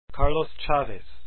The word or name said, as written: chavez